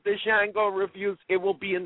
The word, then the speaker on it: Django: Listen, also, Django, the D is silent, sir.